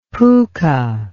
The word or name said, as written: pooka